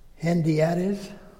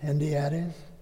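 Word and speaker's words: hendiadys: It's called Hendiades. I think I said that right. So you want to say that? Hendiades? It's H-E-N-D-I-A-D-Y-S.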